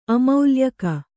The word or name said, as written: ಅಮೌಲ್ಯಕ